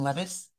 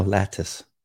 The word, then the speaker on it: lattice: As in lettuce? – No, lattice.